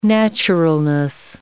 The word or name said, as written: naturalness